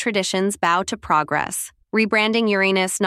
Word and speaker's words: Uranus: The pronunciation Uranus is perfectly valid and widely used in scientific circles.